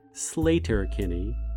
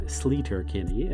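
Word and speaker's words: Sleater-Kinney: By the way, locals pronounce the name of the road Slater Kinney, not Sleater Kinney, and that's how the band has continued to say the name.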